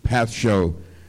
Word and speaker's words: pascho: This word I'm talking about here, pascho, P-A-S-C-H-O, pronounced again, pascho.